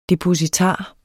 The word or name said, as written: depositar